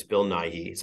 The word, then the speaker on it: Bill Nighy: I mean, I think that, look, this is the trickiest race of the night, and you did, it's Bill Nighy.